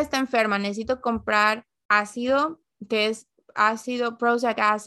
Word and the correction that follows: prúsico: cómo se dice eso. Ácido prúsico.